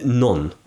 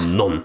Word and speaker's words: non: I always thought it was non. – Yeah. – You don't pronounce the second N.